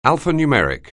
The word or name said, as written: alphanumeric